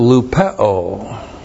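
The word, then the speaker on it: Lupeo (λυπέω): And then the verb lupeo. L-U-P-E long O. Lupeo.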